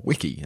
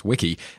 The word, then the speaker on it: wiki: Incidentally, it's also technically pronounced wiki, not wiki,